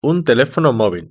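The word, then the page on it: un telefono movil: European Spanish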